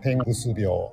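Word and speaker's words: 天狗巣病: 天狗の巣と書いて、うん、天狗病。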